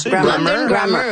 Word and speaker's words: London Grammar: En l'écoute de Bonne Nuit Chérie et Soleil Secret, j'ai pensé à un groupe qui s'appelle London Grammar. Je prononce bien? – Grammar. London Grammar, oui.